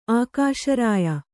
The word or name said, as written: ಆಕಾಶರಾಯ